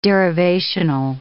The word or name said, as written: derivational